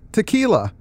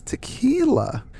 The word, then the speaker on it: Tekla: I've read this for years and only recently discovered I've been pronouncing Tekla incorrectly. An audio version with Zelazny reading was discovered not too long ago, and it turns out he pronounces it exactly like tequila. Oh. So it's tequila.